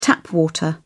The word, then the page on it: tap water: British English